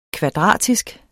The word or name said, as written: kvadratisk